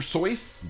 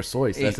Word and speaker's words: Seuss: Apparently, it's Dr. Seuss. – Dr. Seuss. That's how you say it.